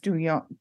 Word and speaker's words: Duyuan: Which I thought it was Dwayne, but it's Duyuan. – Yeah, it's spelled like Duyuan. Dwayne would be, but it's pronounced Duyuan.